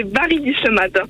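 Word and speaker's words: Barry-d'Islemade: Redonne-moi le nom de ta ville parce que je crois que je l'ai mal prononcé. – Alors, c'est pas une ville, c'est un village, c'est Bari Dilmad.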